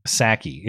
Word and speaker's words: Psaki: Psaki. It's PSAKI is her name. PSA. Jen Psaki.